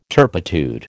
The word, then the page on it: turpitude: American English